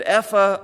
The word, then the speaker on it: ephah: How much was an ephah of barley? An ephah is, or an ephah, it's pronounced either way, was a dry measure of quantity.